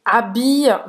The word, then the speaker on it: s'habille: Presta atenção aqui na pronúncia, tá? Que esse habia vai ser esse i longo, né? – Habia.